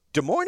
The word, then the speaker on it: Des Moines: Des Moines is a fun town. And why don't you pronounce the S? – I don't know, because they're Iowans.